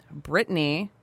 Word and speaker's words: Brittany: He lived in Brittany, and there's a territory dispute.